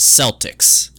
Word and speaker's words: Celtics: Like, I mean, this team is the Celtics. They are the only thing that is ever properly referred to as Celtics. Everything else is Celtic.